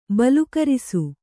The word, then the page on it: ಬಲುಕರಿಸು: balukarisu